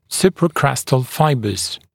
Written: supracrestal fibres

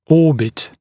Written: orbit

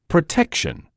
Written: protection

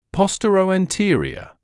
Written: posteroanterior

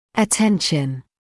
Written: attention

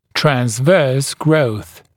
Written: transverse growth

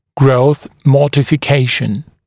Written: growth modification

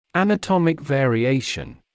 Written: anatomic variation

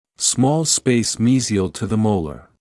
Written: small space mesial to the molar

